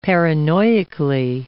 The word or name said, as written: paranoically